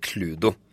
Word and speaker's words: Cluedo: To, jeg tror det er litt delte meninger om hvordan det egentlig er man uttaler det, men jeg velger å kalle det Cludo. Cludo? Cludo. Det er jo Clue det spiller på, på en måte. – Jeg tror jeg alltid har kalt det Cluedo.